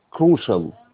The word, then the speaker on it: crucial: فبيقول لك إيه، this precious، أصعب كلمة، precious advice، precious قلنا قيمة، advice نصيحة، proved أثبتت، crucial، crucial هنا CIA هنا بتنطق crucial، والسي الأولى تنطق K.